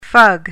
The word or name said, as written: fug